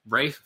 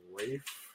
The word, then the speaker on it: Ralph: Rafe Fiennes. It's spelled Ralph. – Rafe? – Mm-hmm. – It's spelled Ralph. – Uh-huh. F-I-E-N-N-E-S. – F-R-A-L-F? – F-I-E-N-N-E-S.